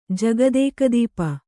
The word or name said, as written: ಜಗದೇಕದೀಪ